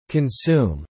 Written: consume

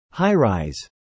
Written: high-rise

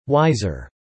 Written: wiser

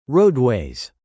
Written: roadways

